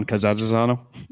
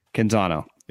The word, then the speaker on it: Canzano: Well, I forwarded you the tweet about like John, how do you say the guy's name in Oregon that covers the Oregon Ducks? John Cazazano? – Canzano.